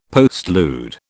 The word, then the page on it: postlude: British English